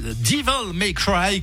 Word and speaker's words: Devil May Cry: On peut prononcer correctement. Devil May Cry.